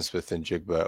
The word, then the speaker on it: Smith-Njigba: uh like in my mind when I think Jaxon Smith-Njigba over route or crosser route, like that's kind of the example, probably.